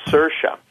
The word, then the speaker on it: Saoirse: and people don't know her because her name's such a screwy spelling, mm-hmm. but it's pronounced Saoirse.